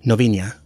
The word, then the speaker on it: Novinha: So I can tell you it's Olhado and Novinha and Marcão, but there's actually in my Nook version there's actually a pronunciation guide for the names.